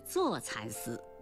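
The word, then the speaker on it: 柞蚕丝: 乍蚕丝错了，应该是坐蚕丝。听众评判正确。木字旁右边放个初来乍到的乍，是个多音字，分别读乍和坐。乍水是一个地名，在陕西省。坐是树名，坐树也就是栗树，叶子可以喂坐蚕。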